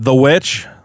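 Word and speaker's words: The VVitch: Well, it's just because it's spelled in the old-timey New England way, where a W was made with two V's. – And not a W? – They didn't have a W back in the day. – So they called it. They called two V's a W? – They would put two V's next to each other, and you were just supposed to know to pronounce it as a W.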